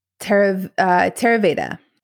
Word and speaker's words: Theravada: So you practice, see if I say this correctly, Theravada Buddhism. – Theravada.